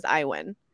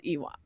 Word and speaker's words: Iwan: Yeah, like, this whole book, in my mind, he was Iwin. And then we made sure and looked it up, and it's Ewan.